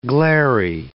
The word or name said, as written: glairy